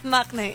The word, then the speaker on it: maknae: Well, I'm maknae Louis here. Is that how you say it? – Maknae, yes, cool. – That's it. That's precisely it.